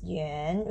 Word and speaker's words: 园: And next word is yuan with Y and yuan sound. Yuan, yuan, second tone. Yuan means garden. Yuan combined is zoo.